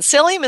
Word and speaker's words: psyllium: And psyllium is spelled with a P, it's P S Y L L I U M.